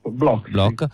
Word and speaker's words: Bloch: che è Mark Bloch, non so se lo pronuncio bene. – Bloch.